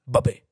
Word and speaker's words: Mbappé: Han säger, man uttalar, Mbappé är fransk. – Mm. På pappret är han fransk nationalitet. På franska säger man Mbappé.